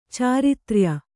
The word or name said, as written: ಚಾರಿತ್ರ್ಯ